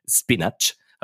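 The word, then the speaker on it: spinacz: Ähm, und die andere Person erraten muss, dann da habe ich ja beim letzten Mal es glorreich geschafft, die Büroklammer auf Polnisch, Spinach, habe ich mir auch gleich gemerkt. ähm, ist es falsch ausgesprochen gewesen oder Nee, es ist genau richtig.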